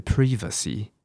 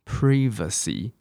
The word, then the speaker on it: privacy: 你说好这个字有很难吗？privacy，我跟你说，英国人念是 privacy。 Privacy，你看跟我们想的不一样，对不对？